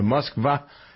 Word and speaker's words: Moskva: Also in league with number 1, the story of the conduct of the war, the Musk, is it, how do we pronounce it? The Moskva is the Russian flagship.